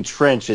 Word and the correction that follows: Tretch: It's pronounced Tretch. – Trench. – Tretch.